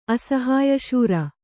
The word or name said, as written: ಅಸಹಾಯಶೂರ